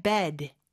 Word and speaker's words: bed: 그 다음에 bed, bed. 에 발음보다는 좀더 약간 눌러주는 느낌의 bad로 해주셔야 돼요. 요게 중요한 게 bad, 나쁜의 bad하고 발음이 좀 헷갈릴 수가 있어요. 그래서 나쁜은 bad, 입을 좀더 크게 벌려주고 침대는 bed, 에 발음으로 좀더 눌러주는 느낌으로 해주시면 됩니다.